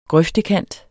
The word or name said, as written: grøftekant